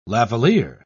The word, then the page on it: lavalliere: British English